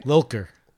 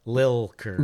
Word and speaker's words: Lilker: Lilker. – What? – Lilker.